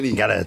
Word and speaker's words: Gallet: Et après avoir cassé d'énervement le bras de notre président, Mathieu Gallet, ce bras avec Mathieu lequel... – Gallet. – En français, on prononce toutes les lettres.